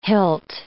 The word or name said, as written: hilt